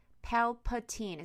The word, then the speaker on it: Palpatine: Palpatine. Is that how you say it? Yep.